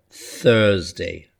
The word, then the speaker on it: Thursday: Thursday, Thursday. So Monday, Tuesday, Wednesday, Thursday. And a th. Get your tongue between your teeth when you're trying to pronounce it.